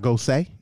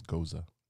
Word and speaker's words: Gose: It's not Gose. It may be Goza. – I think it's Goza. – All right. Well, we got like 3 segments. We'll try to see how that works. – Goza.